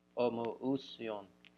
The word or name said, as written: omoousion